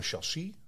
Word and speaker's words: chassis: Ehm, op een chassis. – Ja. Zeg ik dat goed? Ja, klopt helemaal.